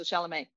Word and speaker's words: Chalamet: You don't hear the T at the end. – Okay, so Chalamet.